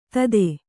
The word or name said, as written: ಧೋತಿ